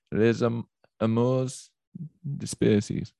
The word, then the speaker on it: Les amours dispersées: How would you pronounce the title of it again? – Les amours dispersées. – Les amours dispersées. – Yeah, quite good.